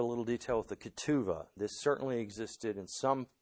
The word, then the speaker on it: ketuvah: In Hebrew, this betrothal contract is called ketuvah, K-E-T-U-V-A-H. Some spell it with a B there instead of a V.